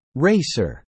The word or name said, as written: racer